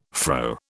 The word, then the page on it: fro: British English